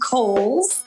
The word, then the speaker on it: Coles: It is pronounced Coles. George Cole was an early land owner developer out in the East County area. So Coles Mountain, like the department store.